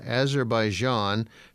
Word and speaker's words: Azerbaijan: Italy, France, Germany, Guatemala, Honduras, Mexico, India, Kenya, Cuba, Algeria, Azerbaijan, Philippines, Republic of Congo, Romania, Russia, Venezuela, Vietnam, and Zimbabwe.